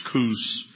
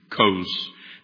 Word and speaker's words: Kos: In the Greek, those two O's are really one letter. It's omega. And you can always tell what the vowel in Greek, how you should pronounce it by the way of its name. See, so Omicron, would be a short O, but Omega, you see, you use the long O there, at least in English. So instead of Kush or Kus, this would probably should be Kos.